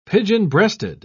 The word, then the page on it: pigeon-breasted: British English